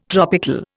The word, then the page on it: tropical: Indian